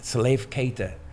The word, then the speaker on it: Salif Keita: Salif Keita.